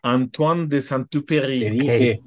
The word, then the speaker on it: Antoine de Saint-Exupéry: Antoine de Saint-Exupéry.